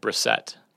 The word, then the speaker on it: Brissett: According to the University of Florida pronunciation guide, it is, in fact, Brissett.